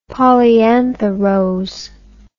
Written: polyantha rose

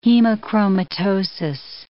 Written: hemochromatosis

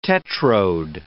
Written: tetrode

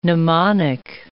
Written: pneumonic

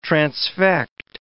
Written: transfect

